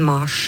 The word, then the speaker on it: Mars: I latin så ble navnet til krigsguden Mars uttalt, nettopp med lang A. Mars.